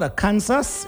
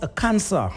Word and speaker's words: Arkansas: And yes, did I say what, a Kansas? It is pronounced a cancer.